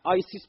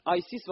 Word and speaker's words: آیسیس: 2 تا خواهر برادرها ایسیس و آیسیس، بهتر بیم تلفظ درست، آیسیس و اوسیریس با هم ازدواج کردن.